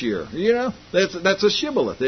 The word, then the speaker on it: Shibboleth: And said they unto him, Say now Shibboleth. And he said, Sibboleth. For he could not frame to pronounce it right.